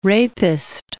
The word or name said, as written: rapist